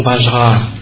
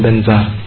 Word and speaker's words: Vajra: Trên Om Vajra thì họ đọc là Om Benza.